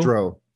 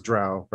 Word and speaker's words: drow: Because I pronounce drow improperly. – Or dro? – Dro. I say dro, everybody else says drow, right? And I'm like, but it's like crow.